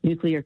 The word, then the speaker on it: nuclear: And then the third one that people mispronounce all the time is when they say nuclear, not correct. So if it's cloudy today, and maybe, but tomorrow it's going to be a new clear day. Just say nuclear, and you will pronounce nuclear correctly.